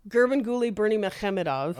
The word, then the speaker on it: Gurbanguly Berdimuhamedov: Gurbanguly Berdimuhamedov.